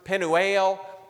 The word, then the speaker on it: Penuel: as they would pronounce it in the Hebrew, Penuel.